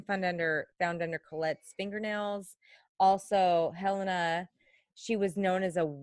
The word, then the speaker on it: Helena: which was one of them was Helena. I don't want to say Helena because my sister's name is Helena, and it's not pronounced that way. I want to say Helena Stokely.